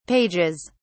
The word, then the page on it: pages: British English